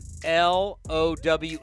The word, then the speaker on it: Lowe: By the way, is there any player other in the history of baseball that's L O W E and pronounced Low?